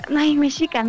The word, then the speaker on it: mexica: En este país podemos ver desde las ruinas la antigua operación de los imperios mayas o mexica, mexica, mexica, ¿no? Se dice así.